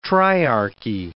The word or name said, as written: triarchy